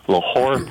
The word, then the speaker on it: Lahore: I'm thinking, if I can pronounce this right, Lahore, Pakistan. – That's correct.